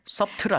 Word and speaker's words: subtle: S-U-B-T-L-E. There's a B in there. – Subtle 아니군요. – No, we don't pronounce the B. Right. It's subtle. And